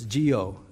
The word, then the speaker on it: Guyot: It looks like Guyot, but it's Geo is the way you pronounce that, I'm told.